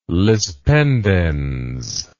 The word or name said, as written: lis pendens